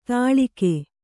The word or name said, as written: ತಳಿಕೆ